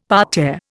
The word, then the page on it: parterre: Indian